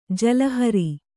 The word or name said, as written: ಜಲಹರಿ